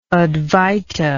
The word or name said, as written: advaita